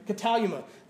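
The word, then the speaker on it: katalyma: That's not how it's spelled in, in Greek, but I mean, I, I, so he's going to kill me. But that is how it's phonetically sounded, right? Cataluma.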